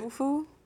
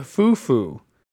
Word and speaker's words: fufu: Hulu? What did you say? – Fufu? – Yeah, foo-foo. Rhymes with poo-poo.